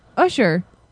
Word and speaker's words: Usher: Right next to him is Usher.